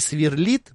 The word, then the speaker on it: сверлит: Два слова могут поменять ударение. Например, такие варианты, как «вручит» и сверлит могут произноситься вручит теперь вручит и сверлит.